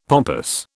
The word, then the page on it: pompous: British English